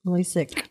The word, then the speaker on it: LASIK: What is, am I saying it right? Is it LASIK? – LASIK.